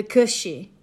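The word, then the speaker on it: cù-sìth: I have some idea how to pronounce Welsh, but it's C-W-N and then another word, A-N-N-W-N. Cwn Anhwyn, possibly. So if we go to the Scottish folklore, the Cushy, which is C-U-S-I-T-H,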